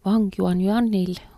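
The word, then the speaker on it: Wang Yuanyuan (王媛媛): Kõigepealt ma palusin Wang Yuanyuanil oma nime hääldada. – My name is Wang Yuanyuan. Wang is my last name, family name. Yuanyuan is my first name.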